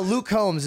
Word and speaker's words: Luke Combs: No, but Luke Combs is the only artist that did I even say his name right? – Yeah, I play his shit and I'm like locked in.